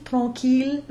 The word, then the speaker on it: tranquille: We talked a lot about the ILLE being the J glide or the yod, but we also mentioned the exceptions, mille, tranquille, ville, where the L is pronounced.